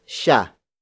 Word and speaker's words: siya: The next word is siya. Siya. Siya. Siya is spelled as S-I-Y-A.